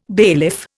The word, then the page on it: bailiff: Indian